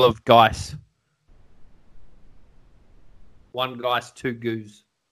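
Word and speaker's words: gose: Well, we've had a couple of lambics and a goose. I'm probably going to pronounce that wrong. Anyway, no, you got it. – Got it, goose.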